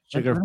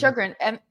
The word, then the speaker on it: Chagrin: Sugar Falls? – Sugar. Chagrin.